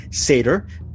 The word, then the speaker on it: Sator: It's called Sator, S-A-T-O-R. The way I pronounce things, I thought it was called Sator, but it's Sator. It's Sator.